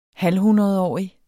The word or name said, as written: halvhundredårig